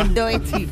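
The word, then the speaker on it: Doechii: Y a ver, ¿esto cómo se pronuncia? – Doechi. – Muy bien.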